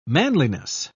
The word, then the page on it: manliness: British English